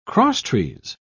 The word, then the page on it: crosstrees: British English